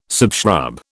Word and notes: subshrub: British English

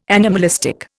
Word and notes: anomalistic: Indian

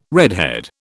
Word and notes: redhead: British English

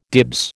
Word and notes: dibs: American English

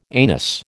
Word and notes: anus: American English

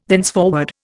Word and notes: thenceforward: Indian

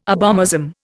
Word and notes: abomasum: Indian

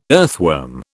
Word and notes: earthworm: British English

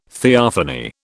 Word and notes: theophany: British English

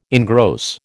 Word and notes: engross: American English

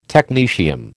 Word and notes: technetium: American English

